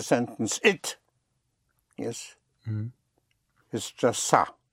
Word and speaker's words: ça: Ce n'est pas, spelled C-E-N apostrophe E-S-T, P-A-S. Ce n'est pas. But otherwise, it by itself is ça. Spelled C-A, and it has a little hook on the C to give you the pronunciation of an S instead of ka, make it ça.